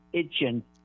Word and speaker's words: Etienne: It's now pronounced Itchin.